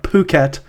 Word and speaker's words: Phuket: So in Thai, PH is always pronounced puh, except for pho, which is very confusing. But yeah. But that, yes, this is what I found. Phuket.